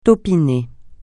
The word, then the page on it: taupinée: francais